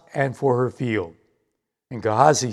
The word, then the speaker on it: Gehazi: Now the king was talking with Gehazi, or more properly pronounced, Gehazi, the servant of the man of God, saying, "Please relate to me all the great things that Elisha has done."